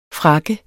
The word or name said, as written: frakke